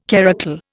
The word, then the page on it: karakul: Indian